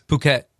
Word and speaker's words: Phuket: Yeah, it's Phuket. – With no H? – Yeah. – Okay, so the H is silent.